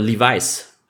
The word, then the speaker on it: Levis: Will Lewis oder Lewis oder wie man immer ausspricht und Anthony Levis. Richardson. Lewis. So, oder Levi's spricht man. Nee, nee, wirklich Levis. Er wird wirklich immer okay. Levis auch in den amerikanischen Medien genannt.